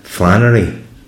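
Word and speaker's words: flânerie: method this idea of um, flanerie. Am I pronouncing it right? – Um, yeah.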